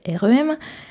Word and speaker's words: R.E.M.: qu'on prononce souvent à la française R.E.M.